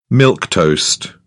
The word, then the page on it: milquetoast: British English